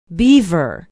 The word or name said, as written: beaver